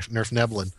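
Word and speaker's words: svirfneblin: Yes, I actually pronounced that right, Nerf Neblin.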